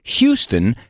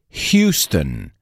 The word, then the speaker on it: Houston: La ciudad Houston se pronuncia Houston.